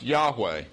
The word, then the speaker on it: Yahweh: It's the four consonants, YHWH, pronounced Yahweh.